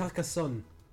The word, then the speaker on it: Carcassonne: Is that how you pronounce it? It's Carcassonne. – Carcassonne. – You're so English. – It's a French word.